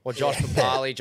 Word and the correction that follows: Papali'i: Josh Papali'i now.